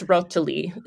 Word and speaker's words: rotoli: In Italian, it's rotoli.